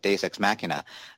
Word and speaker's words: deus ex machina: Uh, Dimitri, what do you think about these two fights, the sort of deus machina, um, sorry, I mispronounced that, deus ex machina, uh, version of, of the way both fights ended? – It was great to see Jay come back.